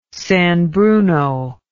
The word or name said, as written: San Bruno